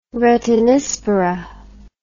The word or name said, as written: retinispora